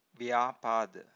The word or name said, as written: vyapada